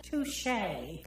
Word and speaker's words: touché: Tushy. – It's pronounced... How did you pronounce it? – Touché. – There you go!